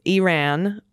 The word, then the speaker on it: Iran: Please pronounce Iran as its people do. Iran or and Iranian. Okay, Iran, Iranian. Got it.